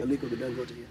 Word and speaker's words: Dangote: But you know and have a relationship with Aliko Dangote. Am I saying his name correctly? – Aliko Dangote, yeah.